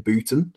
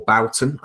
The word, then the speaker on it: Bouton: which is Ball 4 by Jim Bouton, or Bouton. I don't know how you pronounce that properly. – I think it's Bouton.